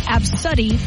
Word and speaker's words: Abcde: Some of the responses, A, B, C, D, E, pronounced Absuddy.